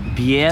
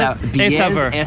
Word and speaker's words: Bière: We are in Chambly, Quebec, at the Beer de Saveur. – Bière. – Did I pronounce it? – Bière. – Saveur. – Saveur. – Okay, I'm not French.